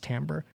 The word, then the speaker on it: timbre: It's spelled timbre, but it's pronounced timbre.